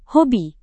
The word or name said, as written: hobi